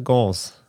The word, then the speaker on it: Gans: Ja, Franzose, ne? Gans. – Gans, ja, würde ich auch sagen.